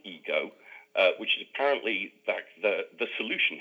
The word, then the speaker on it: Igo: called Ego, spelt I-G-O. Now, I wouldn't have known it was spelled I-G-O if you hadn't pointed that out. Again, how have they decided that's pronounced Ego?